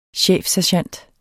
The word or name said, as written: chefsergent